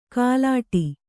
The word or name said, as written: ಕಲ್ಲೆಡೆ